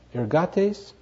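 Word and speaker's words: Ergates (ἐργάτης): How do you pronounce that? Ergates? Yes. Is that close enough? – Yes, perfect.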